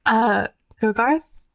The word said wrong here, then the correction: Gargith: I said that wrong, didn't I? – Yeah, Gargith.